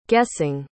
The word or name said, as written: guessing